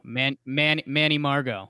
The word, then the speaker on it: Margot: The Rays traded Emilio Pagan to the Padres for center fielder Margot Murgat. Is that how you say his name? – Manny Margot.